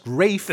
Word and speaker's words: Ralph: He's so legit, his name is spelled Ralph, but it's pronounced Rafe.